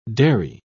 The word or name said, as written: derry